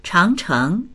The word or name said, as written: chang2cheng2